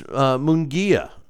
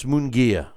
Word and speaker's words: Munguia: I'm pronouncing it wrong again. It's Munguia, right? What did I say? Munguia? Okay, yeah, it's Munguia.